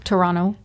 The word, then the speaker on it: Toronto: Did I say that correctly? Toronto. – That's correct. You do not pronounce the second T.